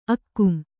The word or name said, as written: ಆಗಂ